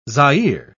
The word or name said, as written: Zaire